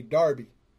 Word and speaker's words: Derby: Now, it looks like it's spelled Derby, but in England they say Darby.